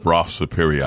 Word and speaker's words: Brough: Yeah, I think it's pronounced Bra Superior, I think. But B-R-A-U. – B-R-O-U-G-H. – Yeah, which is... – In England, it's a Bra.